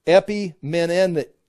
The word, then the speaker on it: Epimenides: And the prophet who says this is Epimenides. Epimenides. There, that's how you say it.